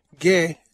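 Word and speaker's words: ge (γῆ): I think what it's referring to is, okay, there are two words that are translated as world or earth, ge, g, long e, and cosmos.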